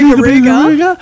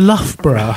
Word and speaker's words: Loughborough: And people said, where the hell is Lugabugaruga? And eventually they worked out they meant Loughborough.